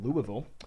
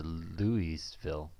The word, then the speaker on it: Louisville: Cornerback from Louisville. Mm-hmm. I think putting him. – It's Louisville. – Louisville. – Louisville. – I'm worried about pronouncing his first name, but I'm pronouncing the school wrong.